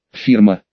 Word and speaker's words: фирма: Фирма». «Именно так, с ударением на последнем слоге», – уважительно заявил он.